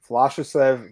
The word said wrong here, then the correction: Vyacheslav: I would say Sergey Gandoshko, Ustur Magomed, Gadzir Dadov, and Vyacheslav Vasilevsky.